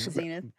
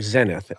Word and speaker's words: zenith: called Zenith, as Zenith. We Americans say, or Zenith, as the British say.